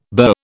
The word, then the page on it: bur: British English